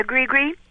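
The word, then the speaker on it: gris-gris: Is it a gree-gree? How do you pronounce it? – Yes, that's right. A gree-gree.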